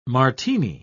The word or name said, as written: martini